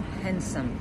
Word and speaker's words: handsome: Пишемо handsome, читаємо handsome. He's so handsome, eating his sandwich in the middle of Wednesday. What can go wrong? Silent D.